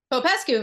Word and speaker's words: Popescu: Adriana, how do I say your last name? Popescu? – Popescu, very good, yes.